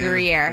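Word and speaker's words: Gruyère: French onion soup, got ciabatta croutons, Gruyere cheese. – It's pronounced Gruyere, but that's okay.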